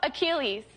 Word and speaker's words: Achilles: Mythological hero Achilles.